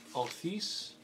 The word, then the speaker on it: Althiss: Althis. So it's A-L-T-H-I-S-S. – Gosh, sorry, I lost my notes. – Spell that again. – A-L-T-H-I-S-S.